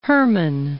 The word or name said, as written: Herman